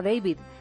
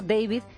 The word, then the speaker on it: David: lo pronuncia como si fuera inglés y resulta que el pinchadiscos ese es francés. Será David, hijo mío, le ha dicho Tony.